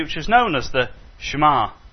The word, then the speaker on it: Shema: Some people say Shema. It's a Hebrew word which means hear. Shema. The stress is on the ma, isn't it? Yeah, Shema.